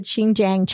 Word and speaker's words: Xinjiang: Okay, so I looked it up. I heard it. That's how you pronounce it. Xinjiang, China, over like the past 2 years.